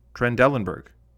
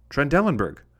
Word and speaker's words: Trendelenburg: A voice came from the stands, "Trendelenburg." The surgeon persisted with this pronunciation. From the stands came again, "Trendelenburg. I am Trendelenburg."